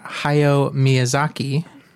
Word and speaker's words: Hayao Miyazaki: So we're going to talk about Hayao Miyazaki. Think I'm pronouncing that correctly. – That's how I've heard it, yes. – Yeah, about. That's how you pronounce that.